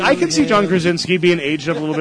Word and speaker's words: Krasinski: So if we're gonna have someone like John Krasinski, right?